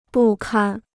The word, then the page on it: 不堪: bù kān